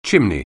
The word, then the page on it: chimney: anglais